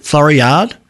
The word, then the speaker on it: Floriade: With Florida. How do you pronounce it? Floriade.